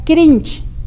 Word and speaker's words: cringe: se le ha puesto la palabra cringe. Cringe es como se pronuncia, pero se escribe cringe. C-R-I-N-G-E.